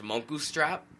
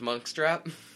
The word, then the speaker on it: Munkustrap: It's also funny because Monkstrap is spelled Munkustrap. So it looks like it should be pronounced Monkustrap, but it's pronounced Monkstrap.